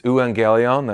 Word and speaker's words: euangelion: It's euangelion, that's how you pronounce that,